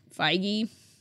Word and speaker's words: Feige: How do you pronounce his name? Feige? – That's how I pronounce it. I don't know if I could be wrong. Kevin Feige.